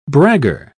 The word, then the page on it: bragger: British English